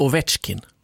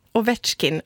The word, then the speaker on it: Ovechkin: Alexander Ovechkin. – Ovechkin.